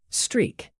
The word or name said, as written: streak